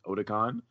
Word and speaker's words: Otakon: I believe that's the way you pronounce it, Otakon.